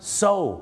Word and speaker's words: sew: so